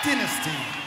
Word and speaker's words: Dynasty: That's how you say it. Dynasty. And we're in your neck of the woods, so we will say it as you say it. Back home, it's Dynasty.